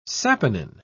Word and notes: saponin: British English

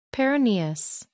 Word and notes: peroneus: American English

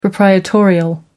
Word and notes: proprietorial: American English

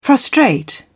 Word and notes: frustrate: British English

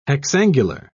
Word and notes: hexangular: British English

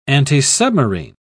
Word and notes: antisubmarine: British English

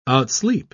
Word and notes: outsleep: British English

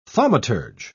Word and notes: thaumaturge: British English

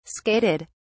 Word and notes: skated: British English

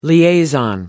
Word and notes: liaison: American English

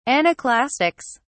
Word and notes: anaclastics: British English

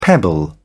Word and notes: pebble: British English

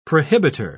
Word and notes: prohibiter: British English

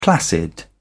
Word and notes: placid: British English

